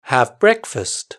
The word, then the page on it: have breakfast: British English